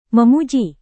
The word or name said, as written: memuji